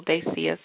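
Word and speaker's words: Daycia: If you're a new listener and you don't know how to spell my name, it's D-A-Y-C-I-A-A. Phonetically spelled.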